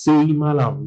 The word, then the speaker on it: Sai Malam: The pronunciation as Sai Malam, just like Sai Baba, where they call Buhari, the late Buhari.